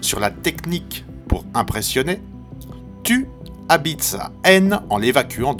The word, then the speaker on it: Thou: Tu, orthographié T-H-O-U, mais bien prononcé tu, est ce genre d'énergumène.